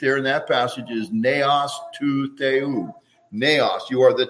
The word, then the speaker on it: naos: The word used for temple here is the word naos. It's okay to learn a little Greek. Doesn't hurt, okay? Naos, okay? In Greek, N-E, omicron, short O-S, N-A-O-S, naos.